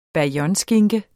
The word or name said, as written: BAYONNESKINKE